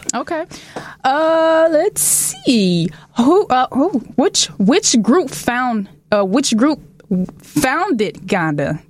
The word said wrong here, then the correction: Ghana: Did I say that right? Did that sound right? – No. Which group founded Ghana?